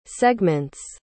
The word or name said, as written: segments